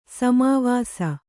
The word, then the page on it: ಸಮಾವಾಸ: samāvāsa